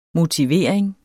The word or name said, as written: motivering